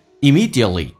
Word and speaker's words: immediately: 第二个是 immediately，那个 T 是不发音的，因为 T 跟 R 我们刚刚有讲过 T 是不发音。